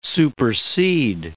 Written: supersede

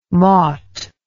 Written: mought

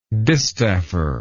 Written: distaffer

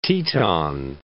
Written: Teton Range

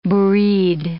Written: bourride

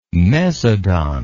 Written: mesodont

